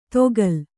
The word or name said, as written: ತೊಗಲ್